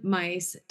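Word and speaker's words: Myss: And that's what I have noticed, especially this summer, because I was listening to Carolyn Mice talk about, it's spelled M-Y-S-S. I don't know why it said mice, but it is.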